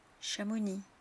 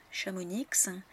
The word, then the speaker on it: Chamonix: Beaucoup de Français se trompent et prononcent ces noms de villes de travers en disant Samoëns, Cassis, Chamonix, Avoriaz. Mais en fait, les gens qui vivent dans ces régions-là, dans ces villes-là, ne prononcent pas la lettre finale.